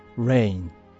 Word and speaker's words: Reign: NVIDIA Reign、治世、支配するということです。スペルは REIGN、降る雨と同じ発音ですね。 Reign。